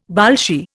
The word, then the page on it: Bolshie: Indian